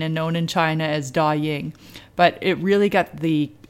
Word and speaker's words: Douyin: Douyin.